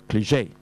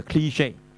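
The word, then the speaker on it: cliché: 但係有兩個發音嘅，原來美國同英國都有啲唔同嘅。一個咧，但係我已經撈亂咗啦，大家上網睇下。一個咧就係就 cliche，一個就 cliche。即係嗰個 cli 就高音啲嘅 cliche 係啦，兩個發音嘅，咁但係即係陳腔濫調啦。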